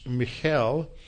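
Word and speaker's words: Michal: The Hebrew is a little difficult to pronounce. It's Michel. And for ease of pronunciation, we'll just call her Michelle.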